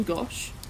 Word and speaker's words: Gosch: Spelled G-O-S-C-H. I hope I'm pronouncing that right. What do you think? – Yeah, that sounds about right to me.